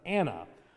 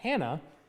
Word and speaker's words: Hannah: One is of Simeon and the other is of Anna, or actually pronounced Hannah in the text.